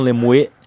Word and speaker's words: Lemuet: Tem aquele Armand Lemuet, acho que o T é mudo, né? Armand Lemuet, que tem o simbionte lá protegendo ele.